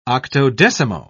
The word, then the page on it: octodecimo: British English